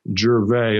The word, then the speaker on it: Gervais: Yeah, G-E-R, G-E-R, V-A-I-S, as in Sam, Gervais. It's like Ricky Gervais, only it's pronounced Gervais.